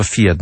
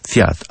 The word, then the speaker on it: fiat: Maryja powiedziała fiat, nie fiat. Bardzo często słyszy się, jak ludzie mówią fiat albo ojciec Pio.